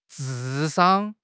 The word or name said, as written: 智商